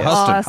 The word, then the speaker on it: Huston: The voice screaming at this ballerina is no other than Morticia Adams herself, Angelica Houston. – So yeah. Huston, awesome. – Huston, yeah.